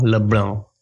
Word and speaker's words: Lebrun: You have to say it with a loose jaw. It's Florent Lebrun. – Florent Lebrun.